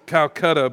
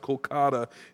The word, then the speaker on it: Kolkata: That's a city that we used to call Calcutta, but it's pronounced Kolkata in the true translation, with a group of college students from the USA.